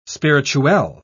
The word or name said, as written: spirituel